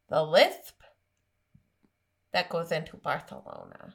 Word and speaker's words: Barcelona: So I told you about this because I myself have visited Barcelona, which is actually how you pronounce it. And I'm very well versed in Spanish. – So Liz, you know this, but there, and it, the, you know, this, And there were some beliefs. There were some beliefs that King Ferdinand was born with a lisp, and that's why it is pronounced Barthelona.